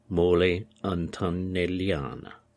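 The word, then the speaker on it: Mole Antonelliana: He's also very famous for designing a very well-known dome called the Mole Antonellian. Mole Antonellian. I'll have to get the pronunciation right. – Morley Antonelliana.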